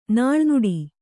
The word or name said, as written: ನಾಳ್ನುಡಿ